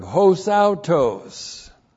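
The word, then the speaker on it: Hosautos (ὡσαύτως): The adverb hos autos looks like this in the Greek. There's the letter H. That's a mouth breathing over the word. That's the letter H in the Greek. H, long O, S, A, U, T, long O, S.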